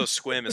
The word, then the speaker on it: Sequim: There's one in Washington in Squim. – Oh, that place sucks. Everyone goes and takes pictures of a bear waving. – Oh. – Also, Squim is spelled Sequim.